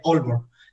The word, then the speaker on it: Aalborg: que se escribe a Aalborg, ¿vale? Pero se pronuncia Aalborg.